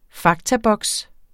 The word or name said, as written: faktaboks